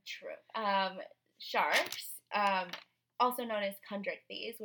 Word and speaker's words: chondrichthyes: But the chondrichthyes, that's how you pronounce it.